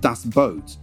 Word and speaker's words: Das Boot: This is the World War II drama Das Boot, or I think it's meant to be pronounced Das Boot, spelt Das Boot.